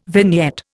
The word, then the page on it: vignette: Indian